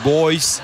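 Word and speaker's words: Bhoys: où dans la prononciation on dit les Boys.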